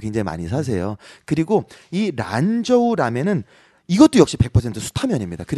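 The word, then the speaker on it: 란저우 (兰州): 그냥 한국어 발음 먼저 말씀드릴게요. 네. 란주라면. – 란주라면. – 원어로는요. 란저우 라면입니다.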